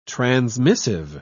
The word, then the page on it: transmissive: British English